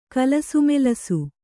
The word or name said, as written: ಕಲಸುಮೆಲಸು